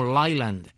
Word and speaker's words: Leyland: Estos vehículos tenían un motor de una marca que conocemos en Cuba, que es la marca Leyland, que ellos pronuncian como Leyland.